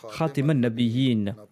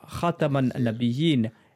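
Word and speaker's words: Khataman Nabiyin (خاتم النبيين): Un jour, Ali est passé à côté d'eux et il a entendu que l'enseignant leur enseignait la prononciation Khatiman Nabiyin. Ali lui a dit « Enseigne-leur Khataman Nabiyin à la place de Khatiman Nabiyin », c'est-à-dire avec le fatha sur la lettre ta au lieu de la kasra sous la lettre ta.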